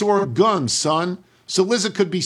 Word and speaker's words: Oregon: That said, I must write in to let you know that you frequently mispronounce Oregon as Oregon. It's Oregon. Oregon.